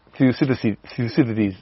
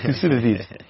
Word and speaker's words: Thucydides: Thucydides. Yeah, that one, yeah. – Yeah, Thucydides.